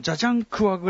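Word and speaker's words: 贾樟柯: あとは、ジャージャンクワというさ、はい、監督も第 6 世。あ、発音、ジャジャンクワぐらいが正しいんですかね。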